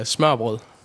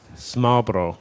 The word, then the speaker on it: smørrebrød: Open-faced sandwich is smørrebrød. – Smørrebrød. – Smørrebrød, yeah.